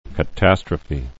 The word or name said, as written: catastrophe